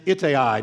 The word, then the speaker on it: Ittai: His name is Ittai. Now, it's spelled I-T-T-A-I.